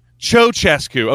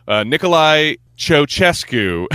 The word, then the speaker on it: Ceaușescu: And I guess it's pronounced like a C-H. Nicolae Ceaușescu.